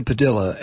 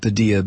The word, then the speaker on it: Padilla: And like I mentioned, Jose Padilla, and I used to pronounce it Padilla, but I was told that this family pronounces it Padilla.